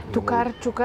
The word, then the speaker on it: Токарчук: Ето миналата година се срещнах с е, Олга Торачук. Чакай, че не... – Токарчук. – Аз наскоро разбрах, че ударението пада на...